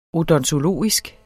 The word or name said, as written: odontologisk